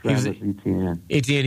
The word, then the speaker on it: Etienne: Etienne? Is that how you say it? – Yeah. – Yeah. – Etienne.